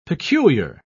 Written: peculiar

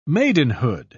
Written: maidenhood

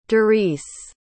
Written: darice

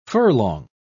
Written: furlong

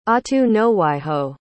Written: ahtunowhiho

